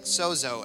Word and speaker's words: sozo: It's a word that you can pronounce like sozo, S-O-Z-O.